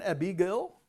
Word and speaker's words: Abigail: Het is, maar in het oude Engels was een Abigail, zo zeg je dat, is de Engelse uitspraak als ik me niet vergis. Maar was een ander woord voor een dienstmeisje. heb jij ook een Abigail?